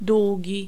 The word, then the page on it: długi: [ˈd̪wuɟi]